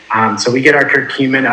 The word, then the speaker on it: curcumin: Say it for me. curcumin, C U R C U M I N.